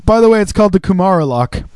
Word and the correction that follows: Kimura: That's not how you pronounce it, though, is it? – Kimura? – Kimura Lock, yeah.